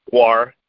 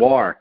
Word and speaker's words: GWAR: War. G-W-A-R. – War. – Pronounced War, yeah. War, War is right.